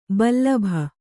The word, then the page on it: ಬಲ್ಬು: balbu